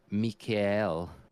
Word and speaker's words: Mikael: But yeah, it was just a shame that Michael or Mikael, that's how you pronounce it in Germany.